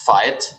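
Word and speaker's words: Veit: It's actually pronounced fight, like the English word fight.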